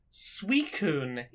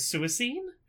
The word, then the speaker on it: Suicune: The water-based legendary dog from the second gen of Pokemon is called Suicune, is its official pronunciation. In my head, I read it for decades as Suicune.